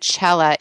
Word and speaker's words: Cella: And also, I usually don't spell my name during the show, but for those people that don't know that Chella is actually C-E-L-L-A, Zappia, Z like zebra, A-P-P-I-A, and Chella, that comes from an Italian pronunciation. So the C is just C and not C-H.